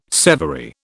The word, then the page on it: severy: British English